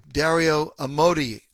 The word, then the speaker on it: Amodei: This is a comment that the CEO of Anthropic, Dario Amodei, I believe is the way it's pronounced, talks about AI and democratically elected governments.